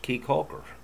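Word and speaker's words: Caye Caulker: Key Calker is pronounced Key Calker.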